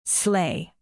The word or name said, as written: sleigh